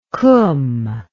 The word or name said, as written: qum